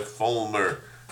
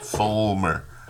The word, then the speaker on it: Fullmer: Fullmer. How do you say it? Fullmer.